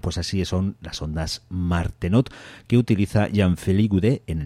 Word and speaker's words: Martenot: Bueno, pues si os habéis fijado atentamente, ahí oíais las ondas Martenot, que así se escribe, como os lo acabo de pronunciar. Ondas Martenot, acabado en T,